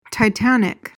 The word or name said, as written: titanic